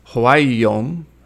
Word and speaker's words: Hwaeom: Sun Wanyong, later known as Huayom, that is spelled H-W-A-E-O-M,